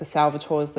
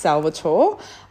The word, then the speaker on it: Salvatore: And that's another thing I will bring up actually while I'm talking about that is it is pronounced Salvatore. I'm not Salvatore, but that's fine. I'll forgive you.